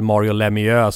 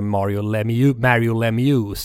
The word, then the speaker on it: Lemieux: Lite så här gränsfall Mario Lemieux som då på Radiosporten blev Mario Lemieux sent i karriären.